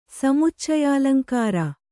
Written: ಸಮುಚ್ಚಯಾಲಂಕಾರ

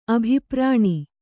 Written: ಅಭಿಪ್ರಾಣಿ

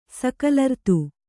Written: ಸಕಲರ್ತು

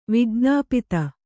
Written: ವಿಜ್ಞಪ್ತಿ

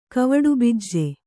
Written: ಕವಡುಬಿಜ್ಜೆ

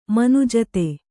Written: ಮನುಜತೆ

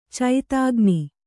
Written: ಚೈತಾಗ್ನಿ

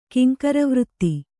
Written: ಕಿಂಕರವೃತ್ತಿ

